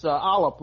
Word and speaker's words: Aalap: It's Alop, like a dollop of sour cream.